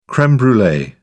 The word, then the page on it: creme brulee: British English